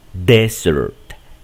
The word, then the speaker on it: desert: Aquí decimos desert, desert, desert. Es en la primera sílaba y acá en la segunda.